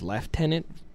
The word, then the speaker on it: lieutenant: And everywhere in the entire world, at least the English, British-speaking part of the world, says left tenant instead of lieutenant. I have no idea why. The word is not spelled left tenant, but they all insisted that they pronounce it right.